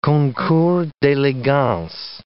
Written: concours d'elegance

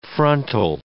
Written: frontal

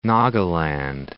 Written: Nagaland

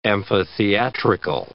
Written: amphitheatrical

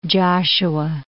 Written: Joshua Tree National Park

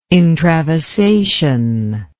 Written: intravasation

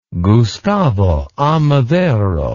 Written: Gustavo A. Madero

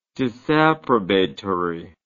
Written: disapprobatory

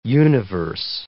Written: universe

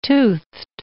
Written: toothed

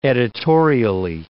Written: editorially

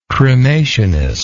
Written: cremationism

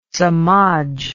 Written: samaj

